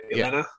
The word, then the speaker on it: Yelena: Did I say that right? Yelena? – Yeah.